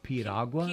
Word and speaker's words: piragua: In New York City, in some of the Latin American neighborhoods, they'll have piragua, which is sort of the same thing. It's like a And how do you pronounce it? – Piragua. – Piragua? – Piragua, yeah.